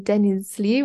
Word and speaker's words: Denizli: I think you were learning Zeybek and Denizli, which you can also correct my pronunciation. – No, you're pretty good.